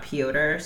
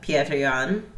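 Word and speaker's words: Petr Yan: I heard it's totally not Piotr, so I will never say that. But Pierre Jan is what I'm hearing it's pronounced.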